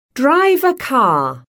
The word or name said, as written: drive a car